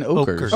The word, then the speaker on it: Ockers: Ucklers. – Ockers. – He puts the phonetic spelling at the bottom of the letter. Yeah. It's Brandon Ockers.